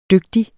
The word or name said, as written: dygtig